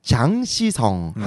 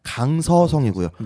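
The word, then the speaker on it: 장시성 (江西): 그리고 장시성. 한국어 발음 강서성이고요. 네네. 강서구할 때랑 똑같아요.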